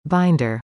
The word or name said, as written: binder